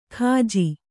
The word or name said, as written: ಕಾಜಿ